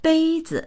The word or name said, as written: bei1zi